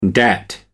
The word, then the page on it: dat: American English